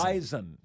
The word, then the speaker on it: Bison: I'm told it's like, you know, they're very particular about the pronunciation. – Correct. – It's Z. – Bison. – Bison.